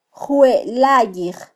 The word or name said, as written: jwelayij